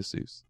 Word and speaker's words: Seuss: And so he came up with the name Dr. Seuss, which I guess he'd used somewhere else, but originally it was pronounced Seuss. Find that? – Yeah, I thought that was interesting. – And then because people kept calling it Seuss and it rhymes with Mother Goose or whatever, you know, anecdotal reason you want to use, he changed it to Seuss.